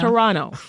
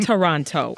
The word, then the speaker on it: Toronto: Yeah, because we don't say the second T, right? It's Toronto. It's not Toronto. – Okay. – As you Americans say.